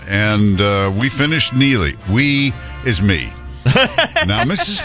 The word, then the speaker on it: Neagley: If you remember, if you read the Reacher books, she's a private investigator who is, now she is, but Frances Neely, spelled N-E-A-G-L-E-Y, but it's pronounced Neely, is a former U.S.